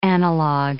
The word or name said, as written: analogue